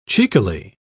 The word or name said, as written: cheekily